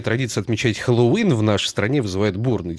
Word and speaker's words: Хэллоуин: Ну, а если взять наш словарь ударений русского языка, то вариант Хэллоуин считается допустимым, а вот все-таки ударение должно быть на последний слог. Хэллоуин.